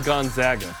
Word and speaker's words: Gonzaga: Women's Hoops hosts number 18 Gonzaga. That's how you say it, by the way. – Mm-hmm. – It's not any other way.